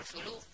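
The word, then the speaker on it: Cthulhu: Pero voy a hacer un homenaje a uno de mis autores favoritos y me voy a tirar por Zulu, que es mi forma de pronunciar al innombrable personaje mitológico creado por Lovecraft.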